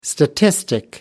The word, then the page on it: statistic: American English